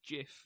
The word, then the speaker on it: GIF: But, GIF and JIF's interesting because the guy who invented it said it should be JIF. – He's wrong. – But if you pronounce it based on how graphics is spelt, it's GIF.